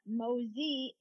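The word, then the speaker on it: Mauzy: And then Crane Mosey, the Mosey is French, so it's M-A-U-Z-Y. And a lot of people mispronounce it.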